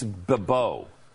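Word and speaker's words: Bibeau: It's B-I-B-E-A-U. It's Babo. Beetleba.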